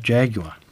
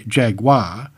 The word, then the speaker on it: Jaguar: Linguists believe that both pronunciations are correct, but that the North Americans say Jaguar actually is a closer approximation of the Tupi word.